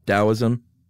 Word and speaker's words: Taoism: who founded Taoism? – Yeah. – Okay, that's what I thought, even though it's spelled with a T.